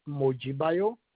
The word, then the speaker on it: Mojibayo: Our first name is Mojibayo, and last name is Olagunju.